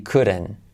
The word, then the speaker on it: couldn't: Saltamos la H, body, T como resuelve, body couldn't. ¿Vale? Body couldn't. Y la T al final, la T al final de couldn't no se dice tampoco, ¿vale?